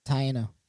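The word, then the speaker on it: Taíno: Taino.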